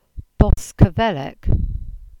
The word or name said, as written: Boskevelek